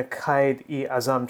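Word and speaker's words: Quaid-e-Azam: In the Qaid-e-Azam Trophy, he scores a ton of runs. – Well pronounced.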